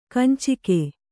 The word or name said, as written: ಕಂಚುಕ